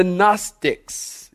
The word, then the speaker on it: Gnostics: There was a group known as the Gnostics. You'll hear this word a lot. You might want to write it down. Gnostics, G-N, the G is silent, G-N-O-S-T-I-C-S, Gnostic.